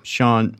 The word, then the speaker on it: Sean: Anyone wants to look at my work, I'm Sean Art.